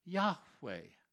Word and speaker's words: Yahweh: and inserted those vowels in between the YHWH to be able to pronounce it Yahweh.